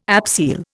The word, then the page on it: abseil: Indian